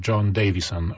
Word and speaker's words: John Davison: Jest ten nowy John Davison. Się okazuje, że tak się to wymawia, bo John Lodge go specjalnie zapowiadał.